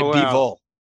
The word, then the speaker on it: Bivol: Bivol. – So it's the stress on B now. Before it was this, the. – I'm sticking with my Bivol.